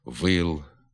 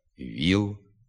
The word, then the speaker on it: віл: Така заміна звука «і» голосним «и» на місці давнього «о» в літературній мові не допускається. Треба вимовляти «віл», «віз», «міст», «ніж», «ніжка», «ніс», «ніч», «сік», «сіль», «сніп», «стіг», «стіл», «тік», «тільки», «ніг», «боліт», «братів».